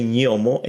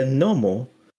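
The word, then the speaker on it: gnomo: You understand why some people would say nyomo and other people would say nomo.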